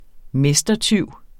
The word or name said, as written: mestertyv